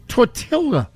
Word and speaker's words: tortilla: Tortilla. – No, he didn't. – Yeah, he did. – I'm not even lying. – I think it's tortilla. – Tortilla? – Only one of the L's is silent.